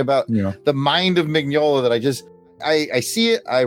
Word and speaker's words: Mignola: Mignola? – Mike Mignola, yeah.